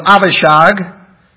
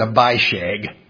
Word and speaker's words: Avishag: That's a nicer way to pronounce the name, isn't it, than Abishag. I just couldn't think, can you pronounce this Abishag? And so I'm going in Hebrew, I'm going to know it's Avishag.